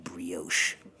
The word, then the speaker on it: brioche: And remember, in my family, because Dax saw the word when he was very young and didn't know what it was, it's called brioche in my house because he was like, what is this brioche? I'm like, it's pronounced brioche, or it was.